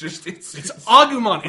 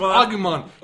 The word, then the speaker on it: Agumon: We're talking about Digimon, and he Digivolved, and Agumon, and it's Agumon! – It's pronounced Agumon, and he's fucking awesome.